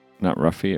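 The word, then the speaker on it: orange roughy: When we went to a restaurant and I ordered Orange Roofie. – Yeah, not Ruffie.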